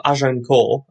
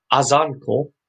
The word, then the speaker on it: Azincourt: But the uh, the Agincourt, the Battle of Agincourt, as it's as it's called, um, is spelt and pronounced wrong because it's been anglicised. So it was actually near. It's Azincourt because it's A Z I N in France, and we call it Agincourt as in A G I N because we've anglicised it.